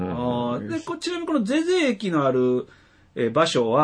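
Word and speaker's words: 膳所: はい、ちなみに、さっき言いました、まあ、当時、大津駅は、ゼゼ駅っていう、あ、はいはい、まあ、読めない字があるんですよね。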